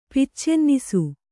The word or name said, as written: ಪಿಚ್ಚೆನ್ನಿಸು